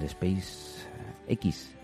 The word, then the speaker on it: SpaceX: Ay, el Space X, X, eh, ¿cómo se dice esto? – SpaceX, SpaceX.